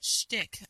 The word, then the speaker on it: shtick: So obvious, but shtick. I never know if I'm saying that properly. – That's the right way to say it.